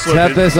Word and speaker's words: Tethys: T-E-T-H-Y-S. How would you say that? Tethys. – Tethys? – Yeah, Tethys. Tethys. – Tethys. – That's what it is, Tethys.